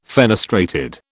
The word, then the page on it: fenestrated: British English